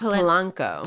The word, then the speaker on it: Polanco: Not Polento, but Polanco. – Polanco. Right? – Yeah.